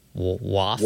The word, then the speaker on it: WAWF: The first is the Wide Area Workflow, WAWF, which is pronounced WAF.